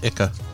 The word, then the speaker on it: IKEA: It's pronounced Ica. – Yes, you're right. It is.